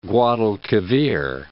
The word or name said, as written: Guadalquivir